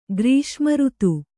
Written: ಗ್ರೀಷ್ಮಋತು

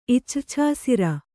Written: ಇಚ್ರ್ಛಾಸಿರ

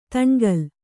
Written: ದುಣಕಲು

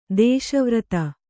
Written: ದೇಶವ್ರತಿ

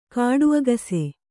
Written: ಕಾಡುಅಗಸೆ

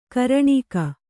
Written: ಘೂರ್ಣಕ

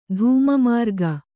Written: ಧೂಮಮಾರ್ಗ

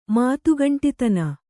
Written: ಮಾತುಗಂಟಿತನ

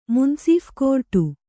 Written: ಮುನಸುಫ್‍ಕೋರ್ಟು

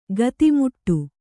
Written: ಖಾತೆಮಾಡು

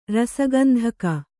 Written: ರಸಗಂಧಕ

